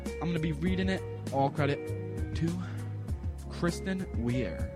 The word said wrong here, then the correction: Kirsten: It's Kirsten Weir. – Oh, yeah, it is Kirsten. Not Kristen.